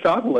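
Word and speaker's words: Schauffele: Xander, is it Shoffley? – Shoffley? – Yeah, help me out here, because when I'm bragging, I got to get the name right. – Xander Shoffley, that's a good pick.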